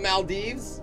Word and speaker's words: Maldives: I was just thinking the Maldives. Am I saying that right? – Yeah.